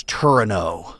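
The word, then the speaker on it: Torino: Kind of like, you know, when we did the Winter Olympics, when NBC did the Winter Olympics in Turin, Italy, they referred to it constantly as Turino, because in Italy, that's the way it's pronounced.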